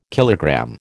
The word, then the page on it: kilogram: American English